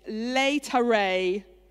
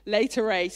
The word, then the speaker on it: Laetare: Today is Laetare, I think that's how you say it, it's Latin, Laetare Sunday, which is kind of about rejoicing and respiting.